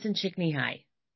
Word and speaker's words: Csikszentmihalyi: It's Mihai Csikszentmihalyi.